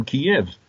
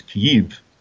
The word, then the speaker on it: Kyiv: We have someone who comes from Kiev, which I think is better pronounced in Ukrainian as Kyiv.